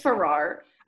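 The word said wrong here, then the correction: Ferrars: I don't know how to say it. – It's Ferris. – Ferris, okay. There's an S.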